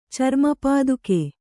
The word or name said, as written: ಚರ್ಮಪಾದುಕೆ